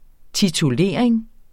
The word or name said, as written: titulering